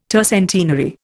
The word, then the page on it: tercentenary: Indian